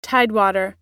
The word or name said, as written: tidewater